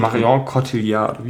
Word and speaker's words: Marion Cotillard: Und Marion Cotillard, wie heißt die? Genau, ja. Das war richtig ausgesprochen. Schön.